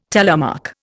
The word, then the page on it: telemark: Indian